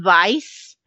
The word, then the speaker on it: Weiss: it's pronounced Vice, mm-hmm. – but it's spelled W-E-I-S-S.